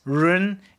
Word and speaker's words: run: But it's run, you know, and it's spelled in pinyin, R-U-N, like run.